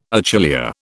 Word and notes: achillea: British English

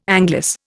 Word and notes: anglice: Indian

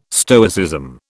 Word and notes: Stoicism: British English

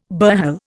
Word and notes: burhel: Indian

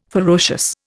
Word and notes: ferocious: Indian

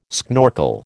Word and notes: schnorkel: American English

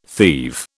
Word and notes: thieve: British English

